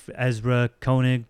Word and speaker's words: Ezra Koenig: Neo Yokio is a animated series created by the guy Ezra Koenig. I'm probably getting that wrong. – No, you said it right.